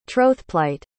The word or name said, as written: trothplight